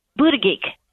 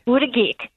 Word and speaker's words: Buttigieg: Buttigieg. That's how it should be pronounced. – Buttigieg. – Buttigieg is how it would be pronounced in just about every language.